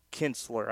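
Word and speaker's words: Kintzler: He pitched just a 1/3 of an inning, and I want to specify the pronunciation, Brandon Kinsler. I understand that I mumbled that there. Homers in the game, Dickerson his 6th for Miami, no homers for Tampa.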